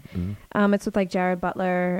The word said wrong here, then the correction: Gerard Butler: and, like, a bunch of Irish actors. – Gerard Butler. – Yeah, I always say that wrong, yeah.